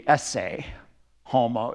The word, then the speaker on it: ecce homo: And his painting, now in classical Latin, which they weren't using at that time, but I liked classical better, it was ecce homo. In their language, it was a soft C, esse homo.